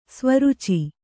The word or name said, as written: ಸ್ವರೂಚಿ